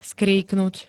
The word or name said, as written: skríknuť